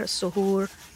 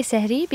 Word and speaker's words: sehri: Some would pronounce it as sahar, suhoor. I believe you pronounce it sehri. Tell us a little bit more about that before we hear the clip. – So I would say sehri because I'm South Asian and my family speaks Urdu.